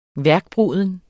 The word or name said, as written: værkbruden